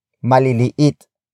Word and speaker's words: maliliit: Word for word, it's ilan, ang, maliliit, na, kamatis, sa, basket.